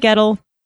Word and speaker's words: Gettle: Donnie Gettle, rhymes with metal,